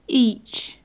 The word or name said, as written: each